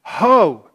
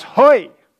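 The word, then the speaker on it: hoi (הוֹי): Ho, actually, in the Hebrew there, that word is pronounced hoi.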